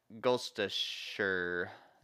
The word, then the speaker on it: Gloucestershire: It's a Gloucestershire is how you say it.